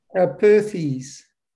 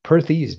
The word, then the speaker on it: Perthes: Is it Perthes disease? – Perthes. – Perthes disease. All right.